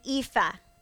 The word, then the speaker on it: ephah: Then she threshed the barley she had gathered, and it amounted to about an ephah." I practiced that word. Ephah is how Google says you pronounce it, which, by the way, is about 30 pounds in weight.